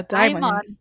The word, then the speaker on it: DXMON: I'm looking at the Korean, the Hangul, and it says DXMON? – Yeah, DXMON. – The X is silent.